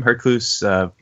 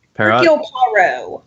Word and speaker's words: Hercule Poirot: How do you pronounce his name? Hercules Poirot. – Hercule Poirot.